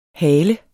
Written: hale

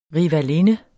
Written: RIVALINDEN

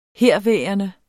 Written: herværende

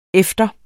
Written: efter

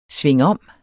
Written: SVINGOMMEN

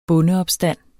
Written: bondeopstand

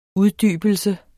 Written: UDDYBELSE

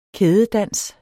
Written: kædedans

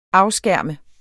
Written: afskærme

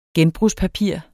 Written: genbrugspapir

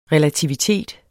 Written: relativitet

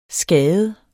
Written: skade

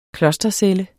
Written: klostercelle

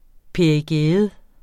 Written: perigæum

